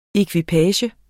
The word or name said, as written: EKVIPAGEN